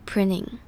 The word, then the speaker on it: printing: 接着我们来看 3D printing 这个 printing 这个字 printing 这边中间的 t 因为后面还有 ing 的关系所以这边的 t 轻轻带过就可以了不用很执着于那个 t 的声音而是念成有点像 ding 有点像字母 d 的发音 printing